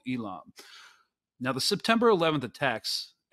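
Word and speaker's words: Eelam: the Tamil anti-government insurgent force, declared the first Elam, is that how you pronounce it? Elam War? – Yep. Perfect, yep.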